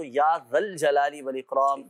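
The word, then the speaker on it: یا ذا الجلال والاکرام: اچھا, بہت سے لوگ یا ذل جلالی پڑھ رہے ہوتے ہیں, سو صحیح تلفظ یہ کہ جب یا کے ساتھ پڑھا جائے گا تو یا ذل جلالی والاکرام, یا ذل جلالی والاکرام, یا ذل جلالی والاکرام, اس انداز سے یہ وظیفہ پڑھنا ہے.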